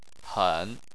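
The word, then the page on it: hen: tone 3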